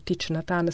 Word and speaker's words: Thich Nhat Hanh: Questa è la meditazione dell'amore che abbiamo sentito recitata da Thich Nhat Hanh, o Thich Nhat Hanh secondo la pronuncia vietnamita,